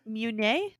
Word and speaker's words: Meunier: Meunier? Meunier. – Meunier. – It's like Monday.